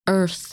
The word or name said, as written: Earth